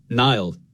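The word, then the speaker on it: Niall: When I was growing up, I would say Nile, like N-I-L-E effectively, like the river. And since I came to America, people couldn't understand what I was saying, so I broke it into two syllables. And now I say my own name the wrong way. I say it Nile. Nile, not Nile.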